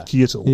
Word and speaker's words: Keitel: Keitel.